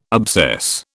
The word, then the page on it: obsess: British English